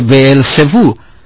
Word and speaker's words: Beelzebú: Decían que echaba fuera los demonios no por medio del poder divino, sino por el poder de Beelzebú, que es la manera griega de pronunciar la palabra Baal Zebub.